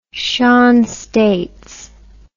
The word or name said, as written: shanstates